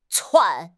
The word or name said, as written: 窜